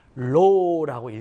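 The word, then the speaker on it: 로우라고 (ρ): 요거는 로우라고 읽고요. 그리스어입니다. 로우라고 읽고 이거는 우리 뭐라고 그러냐면 금속막대의 비저항이라고 불러요.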